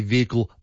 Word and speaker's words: vehicle: used to say vehicle. – Yep. – Like very prominently pronounce the H.